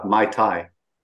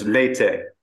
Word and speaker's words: Meitei: I kept saying uh, "mai tai," which is the drink, not the not the community. it's Meitei. Uh, Irom is from the Irom is from the Meitei community, the majority community.